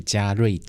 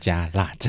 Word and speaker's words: 加蚋: 嗯，不过呢，据说台北捷运站呢，就是有这么一个站名，那到底要叫做加腊站还是加瑞站呢？那因为瑞是昆虫嘛，所以当地的居民觉得，那当然是要用古地名的发音叫做腊，所以呢，各位在台北搭捷运的时候，如果你看到一个加虫内站的话，请念加腊站。